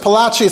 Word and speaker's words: Palachi: So, Rabbi Yosef Palachi, it's spelled with a Gimel apostrophe, but the Sfardim in Spanish, when you had the ch sound, that's apparently what they used, the Gimel apostrophe, to represent that sound.